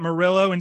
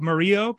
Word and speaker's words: Murillo: I'm saying it Marillo instead of the correct pronunciation of Murillo, but it's spelled M-U-R-I-L-L-O is the last name.